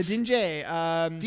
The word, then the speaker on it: Djinn: The Dinge? The D is silent, I believe. – The D-G... – D-J. The Dinge. – D-J-I-N-N.